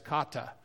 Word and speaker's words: kata: And it's spelled K-A-T-A, kata.